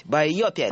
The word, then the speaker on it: байопик: Правильно говорить. Байопик.